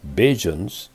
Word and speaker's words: Bajans: Just as Barbadians or Bajans form by omitting the first syllable of Barbadians and by pronouncing the DI with a J sound, are the people who are identified with the country of Barbados by being citizens or their descendants in the Barbadian diaspora.